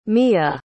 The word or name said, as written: miah